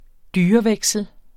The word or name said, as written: dyreveksel